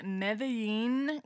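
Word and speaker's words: Medellín: So I grew up in Medellin, Colombia. That's how you say it in Spanish.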